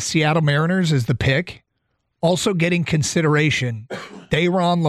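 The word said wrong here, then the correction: Thayron: Lorenzo, Tigers. – Tyron.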